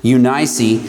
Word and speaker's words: Eunice: When I call to remembrance the unfeigned faith that is in thee, which dwelt first in thy grandmother Lois and thy mother Eunice, that's actually, I believe, the way that's pronounced, Eunice, and I am persuaded that in thee also.